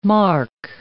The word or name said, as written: marc